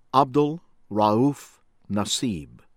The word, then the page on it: Abdul Raouf Nassib: American English; from Yemen